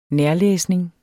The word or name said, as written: nærlæsning